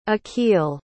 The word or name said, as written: achille